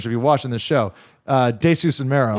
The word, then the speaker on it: Desus: Yeah, it's meant Desus. To rhyme with like these nuts and Jesus.